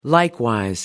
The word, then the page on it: likewise: American English